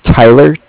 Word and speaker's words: Tyler: My name is Tyler, T-Y-L-E-R. And I'm calling with Cardiff. It's spelled C-A-R-D-I-F-F, as in Frank Frank.